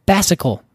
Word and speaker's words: fascicle: Starts with an F and rhymes with fascicle. – Fascicle? – That's it. Yeah, one fascicle.